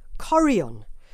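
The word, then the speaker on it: chorion: Apparently, you do it like this. Corion.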